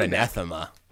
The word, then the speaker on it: anathema: of the word anathema. Anathema. That's great work by you. – I think I butchered the pronunciation, so. – No, I think it's right.